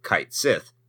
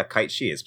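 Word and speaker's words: Cait Sith: they were called Kite Sith. So I was like, oh, It's Gaelic, and it's actually, yeah, Kite Shi. That's how it's pronounced.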